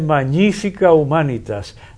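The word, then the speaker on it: Magnifica Humanitas: Y lo van a pronunciar mal eso y no le ponen acento. Van a decir cualquier cosa, pero es Magnífica Humanitas, la próxima encíclica del Papa León.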